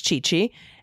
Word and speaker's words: Gigi: Attached are my pod pets, Gigi, or pronounced Chichi, and Saki, named for the blossom, not the booze.